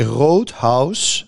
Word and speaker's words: Rothaus: Nee, Roodhuis, hè, dus huis op zijn Duits.